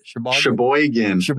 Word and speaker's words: Sheboygan: Sheboygan.